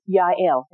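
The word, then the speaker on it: Jael: We're going to win, but it's going to go to Yael. And that's J-A-E-L, but I looked it up and they said it for me, and that's how you say it.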